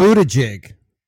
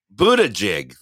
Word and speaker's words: Buttigieg: Buttigieg? Or is that like, I can't remember. – Buttigieg. – Buttigieg.